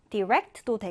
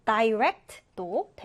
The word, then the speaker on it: direct: 자, 이 단어는 발음이 두 가지가 가능합니다. direct도 되고 또는 direct도 됩니다. direct 또는 direct 이 단어는 일단 직접적인이란 뜻이 있어요.